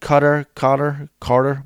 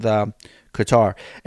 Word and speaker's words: Qatar: Now, I know some people pronounce it Carter. Welcome back, Qatar. I don't know, whatever it is, but we're going with Qatar.